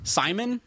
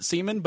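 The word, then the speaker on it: Siemian: Simon. I always pronounced it Seaman, but it's because it's spelled weird.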